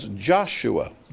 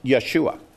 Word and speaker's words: Yeshua: But you and I pronounce that as Joshua. That's American modern English. In Old English, this is pronounced Yeshua.